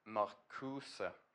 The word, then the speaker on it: Marcuse: Herbert Marcuse, ska, har jag lärt mig om man ska uttala det.